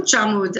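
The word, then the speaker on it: Bourj Hammoud: And my family lived in a little town called Burçamud. Am I saying that right? – You said it very good. – Burçamud.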